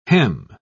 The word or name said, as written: hem